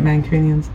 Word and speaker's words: Mancunian: Have you done Mancunian? Mancunian. It's Mancunian.